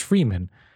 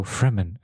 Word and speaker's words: Fremen: I read Freeman every single time. And then they go Fremen. And I'm like, is that how you pronounce it? And I look at the book and what's a Fremen? – It's like, there's only one E.